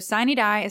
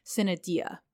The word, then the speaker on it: sine die: Fun fact for those of you who are unaware, the term sine die, spelled S-I-N-E space D-I-E, is Latin, but it has several regional pronunciations in the United States. So sine die is how we say it here in Georgia, but another common pronunciation is sine die.